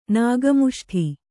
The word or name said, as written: ನಾಗಮುಷ್ಠಿ